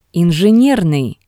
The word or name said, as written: Enjinernii